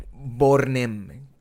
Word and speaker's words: Bornem: Bornem. Piensa en Borne, Borne como el carro.